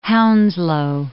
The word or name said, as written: Hounslow